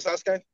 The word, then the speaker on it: Sasuke: Oh, Sasuke. Is that Sasuke? – Yes.